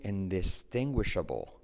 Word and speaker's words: indistinguishable: It's virtually indistinguishable. Ojo a la, a la pronunciación, ¿eh? indistinguible. It's virtually indistinguishable. Indistinguishable. ¿Cuántas is hay? Una, dos, tres, cuatro. Y no escuchas ninguna.